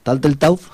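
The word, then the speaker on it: Turteltaub: Vamos a escuchar en este caso pues la suite de la película La Búsqueda, de John Taltletaub, a ver si lo pronuncio bien. – Sí, no, lo has dicho bien.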